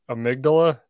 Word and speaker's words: amygdala: Jose Delgado wrote, radio stimulation of different points in the amygdala. Is that how you say that? – That sounds right.